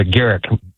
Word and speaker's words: Garrick: That's a Garrick with a hard G.